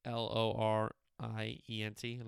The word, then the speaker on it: Lorient: I think that's how you say it. L O R I E N T, Lorient, I think so.